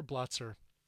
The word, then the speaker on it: Blotzer: And we start off with Sin City by Jack Russell, Mark Kendall, and Bobby, let me get the right pronunciation here, Blotzer.